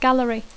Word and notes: gallery: English

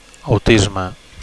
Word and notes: autisme: Catalan

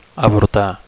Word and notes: avortar: Catalan

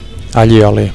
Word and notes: allioli: Catalan

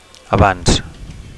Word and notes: avanc: Catalan

